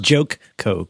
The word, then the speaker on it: JoCo: It's pronounced Joke Co Cruise. – Joke, J-O-C-O Cruise. That spells Joko Cruise.